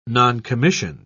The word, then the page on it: noncommissioned: British English